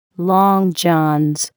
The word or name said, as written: long johns